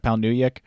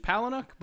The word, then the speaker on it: Palahniuk: It was Rant by Chuck Palahniuk, which I Rant. Never pronounced right. – I was going to say, I think it's Palahniuk, but...